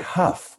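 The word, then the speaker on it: Hough: You were partnered with Derek Howe. Is that how you say his name? Huff? – Derek. – See, this is how out of touch I am with like contemporary anything. Derek Huff.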